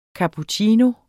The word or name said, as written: cappuccino